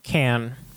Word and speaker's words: Cannes: Is that how you say it? – Cannes. – Damn it.